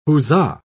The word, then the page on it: huzza: British English